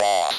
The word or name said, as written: boss